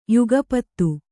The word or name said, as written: ಯುಗಪತ್ತು